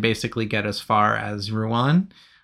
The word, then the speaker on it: Rouen: How do you say it? – Probably Rouen. – Rouen. How's that? – Yeah, perfect.